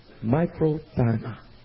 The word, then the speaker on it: makrothymia: Patience comes from the Greek word microthymia. Or maybe microthymia. Here's how you spell it. M-A-K-R-O-T-H-Y-M-I-A.